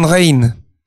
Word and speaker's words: Rijn: C'est comme une Il s'appelait Rembrandt van Rijn. – Ah. – En van, V-A-N, et R-I-J-N, qui se prononce R-A-I-N.